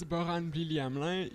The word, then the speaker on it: Borhane Blili-Hamelin: So my name is Borhane Blili-Hamelin. You can just call me Bo. I pronounce my name in French, which is a little challenging.